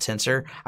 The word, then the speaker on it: TNSR: I think they want to call it Tensor. I, I'm bad with pronouncing things. It's it's TNSR on their website.